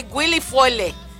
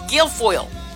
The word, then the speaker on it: Guilfoyle: Quieren hacer burla de nuestros apellidos hispanos, mexicanos, y este que Guilfoyle, y lo puedo pronunciar porque le escuché cómo lo pronunciaron. Si no, yo no sé. I would say Guilfoyle, es Guilfoyle.